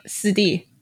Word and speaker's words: shidi: For example, Shizen, Shiji, Sidi. – Shidi? – Shizen. So S-H-I in Chinese is si.